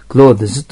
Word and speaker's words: clothes: close という名の発音は close でベロの先を噛んで s の、図の発音する。 closes という発音もありますけど、難しいのでそのまま z の発音で close と言ってもいいんです。